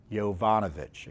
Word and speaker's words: Yovanovich: Yanovich, I think her name, I keep on pronouncing her, mispronouncing her name. It's Yovanovich, excuse me.